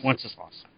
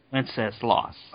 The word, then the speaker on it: Wenceslas: Wenceslas. – Wenceslas? – Yep. – Wenceslas.